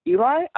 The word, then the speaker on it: Eli: I get Ellie enough that I respond to it just as easily as Eli, but then like halfway through the conversation, I'm like, oh crap, I should correct them.